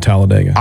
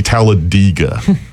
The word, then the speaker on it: Talladega: And that guy that was doing the tour, he said it's technically Talladega. Have you ever, but I mean, I know nobody says that. – No. – But he says that's the correct pronunciation. And I kept thinking, but was I'm not going to say that because everyone says Talladega.